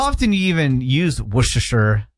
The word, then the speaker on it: Worcestershire: Like W-U-H-S-T-U-H-S-H-R. – Same as like, no kidding, Worcester. – Right, exactly, yeah. Worcester is the county that I'm from in Massachusetts. – Worcestershire. – Worcester, Worcestershire. Worcestershire, S-H-R.